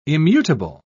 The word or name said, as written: immutable